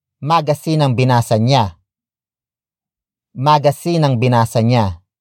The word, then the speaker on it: binasa: After that, we have binasa, which is spelled as B I N A S A.